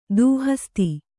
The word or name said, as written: ದೂಹಸ್ತಿ